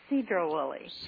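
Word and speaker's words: Sedro-Woolley: Cedro Willie.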